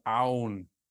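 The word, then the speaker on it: oun (οὖν): Luke begins the verse with a Greek adverb called our, which means therefore. I'm sorry, I pronounced that wrong. I can't read my chicken scratch. It's called oun. Oun, O-U-N, which means therefore.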